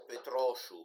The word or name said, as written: petrochou